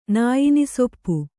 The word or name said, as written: ನಾಯಿನಿಸೊಪ್ಪು